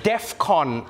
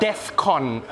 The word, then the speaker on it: DEFCON: Which, by the way, I know this is not the point, but that's not how you say it. I know it's not the main issue here. I know that. But it's DEFCON 3. All right? Not DEFCON 3.